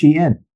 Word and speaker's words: Shein: I just didn't know it was pronounced Shein.